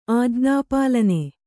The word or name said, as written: ಆಜ್ಞಾಪಾಲನೆ